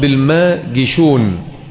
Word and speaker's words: الماجشون: عبد العزيز ابن أبي سلمة هو عبد العزيز ابن عبد الله ابن أبي سلمة، الملقب بالماجشون بكسر الجيم، وهذه الجيم في الحقيقة هي الجيم المعطشة.